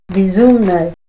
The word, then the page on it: bizumnay: Ninilchik Russian